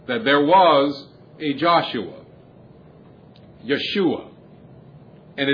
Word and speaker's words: Yeshua: Yeshua is its pronunciation in Hebrew.